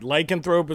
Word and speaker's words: lycanthrope: Is it lycanthrope? – Right. Lycanthrope is another word, sure.